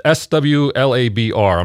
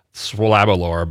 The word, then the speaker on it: SWLABR: And then we listened to Cream with SWLABR. I'm not going to pronounce it Swalabalar because that's wrong.